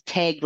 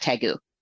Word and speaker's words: Tague: My husband's family, one part of his family says Tague like you're it. The other side says Tague like egg with a T on the front. Either one are perfectly fine, just not Tague. – Okay. It does not rhyme with ragu.